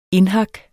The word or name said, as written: indhak